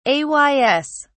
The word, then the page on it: ays: British English